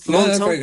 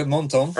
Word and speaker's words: Menton: I'm sorry if I've pronounced the French town of Menton incorrectly. – Menton. Yeah, Menton.